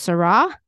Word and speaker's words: Seurat: it's all based on a painting by George Seurat, which is pronounced Seurat if you're not American, and that he was a painter who, from far away, it looks like solid color.